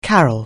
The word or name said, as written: carol